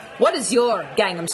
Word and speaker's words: Gangnam: Well, Gangnam is, which is how it's said in Korean, is an area of South Korea, of Seoul, in fact.